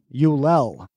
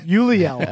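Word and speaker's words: Ulliel: Next name is Gaspard Ulel. – Really good pronunciation. – Uliel.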